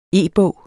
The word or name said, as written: e-bog